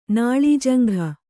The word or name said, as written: ನಾಳೀಜಂಘ